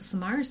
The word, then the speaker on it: Samara: Samara speaking. Hi, Simone. And I'm like, oh, really? – Fucking name, Simone? I said three fucking syllables, you dumb cunt.